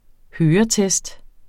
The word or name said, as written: høretest